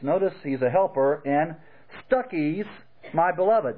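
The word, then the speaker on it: Stachys: Yes, that's the way it's pronounced. Stuckey's. Now, I think of Stuckey's as that place that has nice candy chewies, you know, on the road. Stuckey's. But literally, that's how I pronounce Stuckey's.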